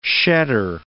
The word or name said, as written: shedder